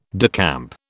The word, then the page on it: decamp: British English